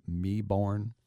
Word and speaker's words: Melbourne: And the Australians, and especially in Vic, as they call Victoria, that is the state in which Melbourne, remember, never pronounce the L or the R, the R, not the L, Meborn.